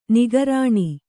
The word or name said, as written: ನಿಃಕರುಣಿ